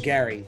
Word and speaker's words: Gerry: Uh, we said Gary. – G E R R Y. They call him Gary, but it's spelled like Jerry.